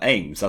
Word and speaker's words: Ames: Ames rooms. Can you spell that? Ames, as in A M E S.